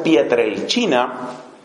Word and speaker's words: Pietrelcina: Los que no conozcan, por favor, del Padre Pío de Pietrelcina, eso se escribe Pietrelcina con C. Si no conoces la historia del Padre Pío de Pietrelcina, se escribe y se pronuncia Pietrelcina.